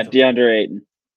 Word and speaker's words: DeAndre Ayton: Now, is it DeAndre Ayton? Is that the name? I want to say it right. Is it DeAndre? – Yeah, DeAndre Ayton.